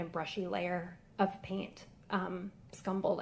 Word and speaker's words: scumbled: The description upstairs says that he used scrumbled paint. I don't know if I pronounced it. Scumbled. – Scumbled? – Yeah.